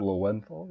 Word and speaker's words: Lowenthal: And I'm pretty sure, so like voice of Spider-Man is Yuri, I don't know how to say his Lowenthal? – Last name. Lowenthal?